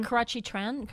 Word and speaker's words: Karrueche: Now, how do we pronounce? What is her name? Karrueche? Karrueche, the girl? Instagram? – Karrueche Tran. Karrueche Tran.